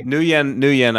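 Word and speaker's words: Nguyen: New Yen, I believe is the way to pronounce it.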